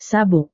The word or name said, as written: sabuk